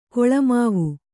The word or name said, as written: ಗುಲಿಮಾವು